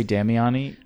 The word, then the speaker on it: Damiani: That's what my brother says it. I say Damiani because I think a lot of my family doesn't say it right because that sounds like almost like damn, like hot damn.